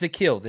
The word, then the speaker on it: Zakelj: It's Zakelj. The J is silent.